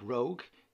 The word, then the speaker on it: Roeg: The original film by great British director Nick Rogue, who obviously fell to earth. – Ooh, Rogue, Don't Look Now. I like X-Men. – It's not spelled like that. – Oh. – It's R-O-E-G. Yeah, no. Rogue. Roeg. – R-O-E-G.